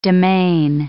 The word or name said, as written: demesne